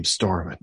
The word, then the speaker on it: Stormin: Or of all the stories he has, and you're just like, so where'd you get the name Stormin? – It's like it rhymes with Norman.